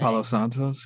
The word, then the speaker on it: Palo Santo: She really needs, I should send her some Palo Santo. How do you say it? Palo Santo? I can't speak today. – Palo Santos, yeah.